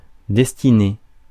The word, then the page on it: destinée: France (Paris); [dɛs.ti.ne]